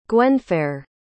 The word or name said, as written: gwenfair